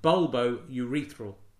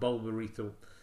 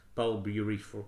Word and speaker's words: bulbourethral: Anyway, the gland is adjacent to the bulb of the penis, hence its name, usually pronounced bulbourethral, in my experience, not bulbourethral, bulbourethral. Well, I don't know what I was saying. Bulbourethral, that is how you say it.